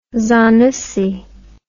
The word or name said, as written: zanussi